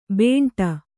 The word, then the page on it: ಬೇಂಟ: kannada